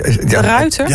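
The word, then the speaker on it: De Ruyter: hoe spreek ik dit uit? – Nou ja. – Mister? Nee? De ruiter? Ja. Sorry.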